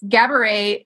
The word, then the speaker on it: Gabourey: And she says in the book, it's Gabourey, it rhymes with cabaret.